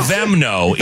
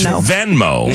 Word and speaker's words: Venmo: You say no. Vemno. It's Venmo. Venmo.